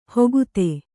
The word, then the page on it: ಹುಗುತೆ: hugute